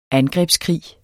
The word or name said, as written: angrebskrig